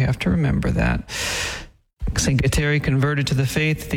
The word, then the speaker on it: Kateri: The Mohawks pronounced Catherine as Kateri, hence the name Kateri Tekakwitha.